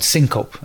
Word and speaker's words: syncope: I would syncope, as it's known.